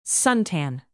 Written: suntan

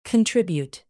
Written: contribute

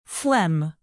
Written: phlegm

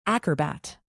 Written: acrobat

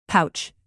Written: pouch

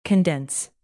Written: condense

